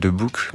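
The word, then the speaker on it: De Boek: Alors, je crois qu'on le dit un peu à la belge de Book.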